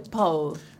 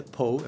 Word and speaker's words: Poe: Bueno, unifiquemos porque en realidad es Edgar Allan Poe, pero algunos acá le decimos Edgar Allan Poe, pero en realidad se dice Poe. – Se dice Poe, efectivamente. – Poe en inglés. – Pero se escribe Poe, P-O-E, como poesía sin sílaba.